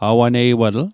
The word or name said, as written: awaneiwatl